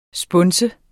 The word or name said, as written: sponse